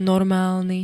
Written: normálny

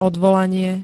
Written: odvolanie

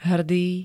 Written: HRDÝ

